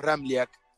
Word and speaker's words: Ramliak: complicado porque tiene tres consonantes juntas y la J se pronuncia como una I. Bien pronunciado, el apellido es Ramliak.